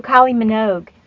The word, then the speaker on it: Kylie Minogue: Well, I was playing Kylie Minaj, whatever her name is. – Oh, Kylie Minogue.